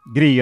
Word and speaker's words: Gree: It rhymes with glee. – Or free. – Or wee! – Gree in Norwegian is a word meaning first light of the sun.